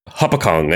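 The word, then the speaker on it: Hopatcong: I thought it was like Hopatcon is what everybody says, but are you from Jersey? – No.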